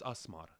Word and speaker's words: Asmar: But in our, you know, when I pronounce it, when we really pronounce it, it's Asmar, you know. But it's actually legally my name is supposed to be L-Asmar, so it's El Asmar.